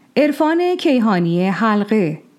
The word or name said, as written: Erfan-e Keyhani Halgheh